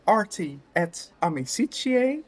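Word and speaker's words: Arti et Amicitiae: En we zijn aan het rokin bij de maatschappij Arti et Amicitie, zeg ik het goed? – Ja, helemaal goed.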